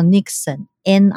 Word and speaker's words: niksen: 文章，他是在讲荷兰现在也在流行一个叫 Nixon，N I K S E N，可是它发音是荷兰文，然后它的意思就是 The power of doing nothing，就是什么都不做，就是允许自己什么都不做，然后过一段时间，它反而更充饱了电，这样子。